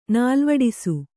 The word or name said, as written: ನಾಲ್ವಡಿಸು